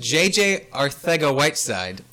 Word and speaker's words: J.J. Arcega-Whiteside: J.J. Arcega-Whiteside, I pronounced it right.